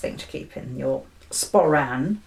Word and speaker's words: sporran: A sporran. – Oh, is that how you say it? – Yes. – Oh. – It's a sporran.